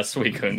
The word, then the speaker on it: Suicune: Yeah, Suicune.